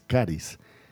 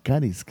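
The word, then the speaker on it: caris (χάρις): La palabra gratitud en el griego es una palabra que es caris. Se escribe X-A-R-I-S, caris, que significa gracia.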